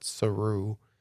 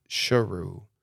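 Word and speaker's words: Sheru: And when he meets his mother is the one who explains to him that his name is not Saru, it's Sharu.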